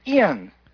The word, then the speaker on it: Jan: So our first email is from Ian. – Okay. – Who spells his name J-A-N, but it's pronounced Ian.